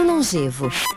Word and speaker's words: longevo: Longevo ou longevo? – Como se pronuncia? – A palavra longevo, ela é com E aberto.